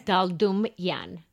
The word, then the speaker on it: Daldumyan: Daldumian.